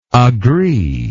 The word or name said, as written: agreeingly